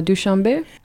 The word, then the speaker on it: Dushanbe: Were you in, is it Dushanbe? Is that how you pronounce the capital? – Yeah, Dushanbe.